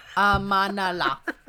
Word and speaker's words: Amanullah: So Saraya was the wife of King Amala Khan. Faced, Nope. Amanullah. Do you want me to do it phonetically? – God. Yeah. – Okay. Amanullah. Does that help? Amanullah.